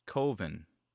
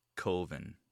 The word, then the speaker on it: Coven: It's Coven, but he pronounces it Coven. – It's pronounced Coven, man. What else could it be pronounced? – Coven, that's the proper pronunciation. – No. Coven sounds like oven, man, and that's just, it doesn't work.